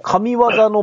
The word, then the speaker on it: 神技: あ、真偽じゃないですね、これ。 – 神技のベストセットらしい。 – 神技のベストですね。へぇ、うん。真偽って読みますよね。 – 真偽って読んでましたね。